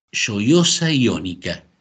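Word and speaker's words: Gioiosa Ionica: Nos vamos a la ciudad de Lloyosa Iónica. ¿Lo dije bien, Guido? – Hola, buenas tardes a todos. Sí, lo dijiste muy bien el nombre, es así.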